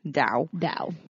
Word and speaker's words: Tao: It's spelled Taoist, but it's pronounced Taoist. – So FYI, if you think like me, Tao. – Tao.